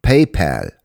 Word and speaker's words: PayPal: Ja, das spricht man so. PayPal.